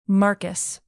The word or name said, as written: marcus